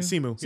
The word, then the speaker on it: Simu: And his, am I saying that right, Simu? – Simu? Yeah.